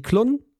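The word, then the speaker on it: Klone: Und wir beginnen mit einer Band, die ja, normalerweise Klon ausgesprochen werden würde. Wir nennen sie Klon, weil so nennen sie sich selber. Hm. Sie kommen aus Frankreich und die französischen Menschen nennen sich Klon.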